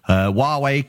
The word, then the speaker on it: Huawei: Huawei cancels their laptop launch in the US because you got the name right then.